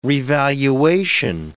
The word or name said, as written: revaluation